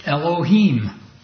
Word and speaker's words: Elohim: And after the meeting, Sister Sylvia Manzik came up to me and she said, "No, you've got that wrong. It's Elohim." So I went home and I got out my Strong's Concordance and looked at the pronunciation of the word, and she was right.